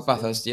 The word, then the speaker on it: Bathurst: So, it would be, I saw this track, I've seen this track on videos, is it pronounced Bathurst? Bathurst? – Oh, Bathurst, yeah, the Australian.